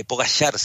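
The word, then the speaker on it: Pogačar: Eh, em, cuando ganó el Tour de Francia, Tadej Pogacar, Poda, eh, Pogachar se debería pronunciar según el esloveno, que es un chico de 22 años que acaba de ganar el Tour de Francia el domingo.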